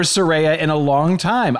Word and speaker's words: Saraya: And now, by the way, I was vehemently defending someone whose name I was mispronouncing the whole time. So The former page in WWE has clarified her real name, and her name in now debuting in AEW is Saraya.